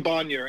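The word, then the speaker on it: Bonier: Tom Bonnier, and it's B-O-N-I-E-R. He's T-B-O-N-I-E-R, at T Bonnier on Twitter.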